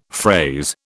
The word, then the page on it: phrase: British English